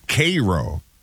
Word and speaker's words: Cairo: It looks like Cairo, but it's Cairo, Nebraska.